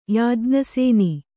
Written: ಯಾಜ್ಞಸೇನಿ

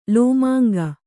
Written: ಲೋಮಾಂಗ

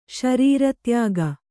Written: ಶರೀರತ್ಯಾಗ

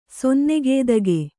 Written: ಸೊನ್ನಗೇದಗೆ

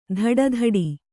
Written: ತೊಡೆದಟ್ಟು

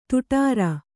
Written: ದಡಾರ

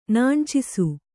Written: ನಾಣ್ಚಿಸು

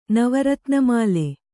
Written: ನವರತ್ನಮಾಲೆ

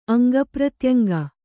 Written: ಅಂಗಪ್ರತ್ಯಂಗ

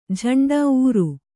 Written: ಊರು

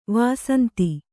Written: ವಸಂತ

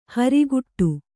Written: ಹರಿಗಡಿ

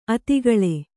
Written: ಅತಿಗಳೆ

